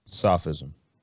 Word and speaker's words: Sophism: And is it pronounced Sophism or Sophism? – Sophism.